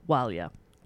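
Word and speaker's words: Ó Máille: Walia is their surname, which is spelt O-M-A-I-L-L-E. That's Walia.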